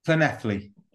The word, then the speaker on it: Llanelli: Leon, continued by saying that one of his best memories is your 58 metre penalty kick to beat Clinelli. Is that you pronounce it? – Llanelli. Yeah, it's difficult.